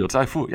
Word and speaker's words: Liu Zaifu: Liu Zaifu. Am I pronouncing that correctly? Liu Zaifu, yeah.